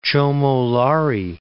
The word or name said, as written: Chomo Lhari